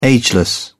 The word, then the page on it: ageless: British English